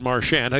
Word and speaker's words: Marchand: So at the World Junior, I said to Brad Marchand, how do you say your name? He said, Marchand, rhymes with sand.